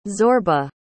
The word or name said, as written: zorba